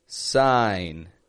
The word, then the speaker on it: sign: S-I-G-N. People pronounce sign as if the G is strong. It's a silent G. Sign. Sign above the line. The I in sign, same as the I in line. Sign above the line.